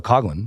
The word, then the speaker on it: Coughlan: At least say, I'm fine with Coughlan, because it's phonetically spelled that way. It's spelled cough and then L-A-N.